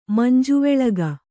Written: ಮಂಜುವೆಳಗ

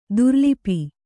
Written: ದುರಾಲಾಪ

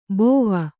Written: ಬೋವ

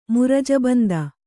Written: ಮುರಜಬಂದ

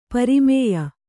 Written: ಪ್ರಮೇಯ

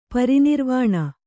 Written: ಪರಿನಿರ್ವಾಣ

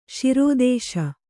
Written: ಶಿರೋದೇಶ

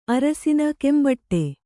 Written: ಕೆಂಬಟ್ಟೆ

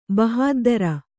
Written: ಬಹದ್ದುರಿ

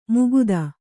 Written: ಮುಕುತ

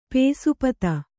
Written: ಪೇಸುಪತ

